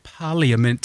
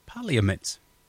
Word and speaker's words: Parliament: Because that's how it's spelt, Parliament. – Yes. – All of the above? – All of the above, yes. – Parliament. – Parliament. – Parliament. It's like Wednesday.